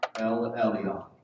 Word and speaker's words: El Elyon: El Elyon. And that is spelled El-Elyon.